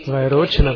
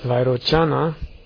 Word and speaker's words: Vairochana: Vairochana. Jedes Sanskrit-Wort auf der letzten Silbe auszusprechen, ist nur der Versuch, einen amerikanischen Ausdrucksweise und Akzent auf Sanskrit auszubringen.